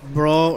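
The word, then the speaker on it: Brough: Brawl. Or Bruff, however you want to pronounce it, motorcycles, were the first to advertise a top speed of over 100 miles per hour on their SS100 in 1924.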